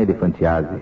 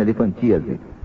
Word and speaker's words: elefantíase: Não é elefantiase. É que ele falou elefantiase e a gente também... Mas, na verdade, a pronúncia é certa. Agora fiquei sabendo. Elefantíase.